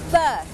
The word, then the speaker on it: berth: berth berth berth อ่านออกเสียงเหมือนกันกับคำว่า birth birth